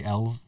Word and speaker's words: Elwes: Cary Elwes. Mm-hmm. That's weird. There's like a W right there. – Yeah, I know. I think the W is kind of silent.